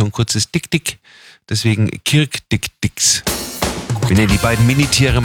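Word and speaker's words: Dikdik: Neu eingezogen ist ein Pärchen Kirk Dick Dix, ausgesprochen so wie Dirk, und das sind afrikanische Zwergantilopen, gerade mal 4 bis 5 Kilo schwer.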